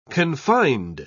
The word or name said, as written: confined